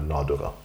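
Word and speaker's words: Nàdurra: Primus Motor bak Oslo Whiskyfestival han, uttaler, eh, han er jo skotsk og er riktignok fra Isle of Sky, men han uttaler Nadura.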